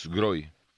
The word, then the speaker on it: Sgroi: Se escribe S-G-R-O-I, Groy.